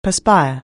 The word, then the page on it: perspire: anglais